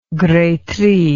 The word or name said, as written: gretry